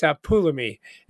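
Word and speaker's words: apulami (ἀπόλλυμι): Well, it turns out it's not apalumai, Matt. Oh, okay. – it, it, it's apulami. It's apulami. That's how it's pronounced.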